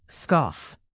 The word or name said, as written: scoff